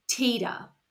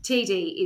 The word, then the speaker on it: Tiede: And the reason is it's spelled T-I-E-D-E. TD? Teeder? Teedy is the way I say it, but I've also heard it pronounced Teeder. So yes. – TD is what it should be.